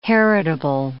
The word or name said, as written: heritable